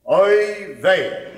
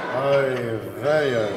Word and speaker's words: oy vey: The word is oy vey.